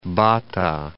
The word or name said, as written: Bata